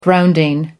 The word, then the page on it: grounding: American English